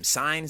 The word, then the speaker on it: Cine: And I'd say, like, they got Dax Hill, Lewis Sine, how do you say his name? Cine from Georgia.